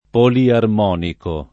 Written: poliarmonico

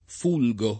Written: fulgere

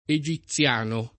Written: egiziano

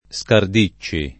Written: Scardicci

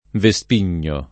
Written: vespigno

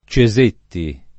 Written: Cesetti

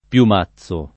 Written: Piumazzo

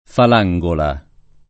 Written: Falangola

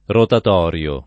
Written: rotatorio

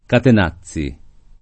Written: Catenazzi

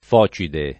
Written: Focide

